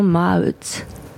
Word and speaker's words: Maeght: Et si c'est elle qui se dit Wandering at la Fondation Maut. – La Fondation Maut, ça s'écrit M-A-E-G-H-T.